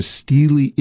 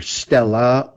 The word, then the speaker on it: stele: It's pronounced either stella or stel, or it could be stele in some people's pronouncing.